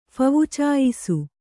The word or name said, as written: ಫವುಚಾಯಿಸು